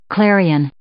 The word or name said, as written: clarion